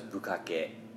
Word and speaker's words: bukkake: If you dare look up the history of bukkake, correct pronunciation by those, bukkake.